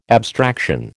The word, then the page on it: abstraction: American English